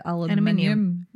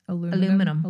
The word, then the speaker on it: aluminium: Is it aluminum or aluminium? – Well, we say aluminium. – Americans say aluminum.